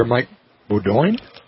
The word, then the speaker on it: Bodoin: We are in Medora today, Steve Bakken and I, and the chief operating officer for the Theodore Roosevelt Medora Foundation is here, Mike Bodoin. – Mike Bodoin, yes. – I'll make sure I'm saying that right. French. – That is French.